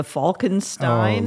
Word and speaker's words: Falkenstein: And then as soon as I added the Falkenstein. – Oh, look out, right? – Everybody got the Fieger right and they got the Steen wrong.